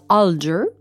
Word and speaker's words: Alger: Elle, elle habite à Sainte-Sabine, qui est située à une dizaine de kilomètres de là, avec sa mère Françoise Alger. Je le prononce en anglais parce que c'est comme ça que je l'ai entendu le plus souvent dans mes recherches.